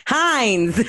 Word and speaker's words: Heinz: It ain't even Hearns. What is it? How you say it? Heinz. I said Hearns. Like I'm from fucking Baltimore. – It's not Hearns.